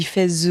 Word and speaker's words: Fès: Ça se dit Fès normalement, mais bon. – Oui, c'est les blagues. – Ça s'écrit F-E accent grave S.